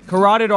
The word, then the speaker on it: carotid: So, with these veins right here, you're not supposed to do it on the front because with the, I always say this wrong, carotid artery. – You said it right.